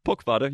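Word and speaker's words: Poc Fada: It's the All-Ireland, how do you pronounce it, Pukfada Championships. – Pukfada, you got it right.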